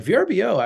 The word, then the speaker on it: Vrbo: So Airbnb is a great example where like VRBO actually existed for like quite some time before Airbnb and after Airbnb. And VRBO has gotten kind of better. I think you're supposed to pronounce it Vrbo now.